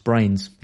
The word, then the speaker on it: Bruyns: Damien Brains says, surname is pronounced Brains. Yeah, I said it right that time. Thank you for that. I wouldn't have thought it was pronounced Brains. Bruyns.